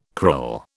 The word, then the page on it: krill: British English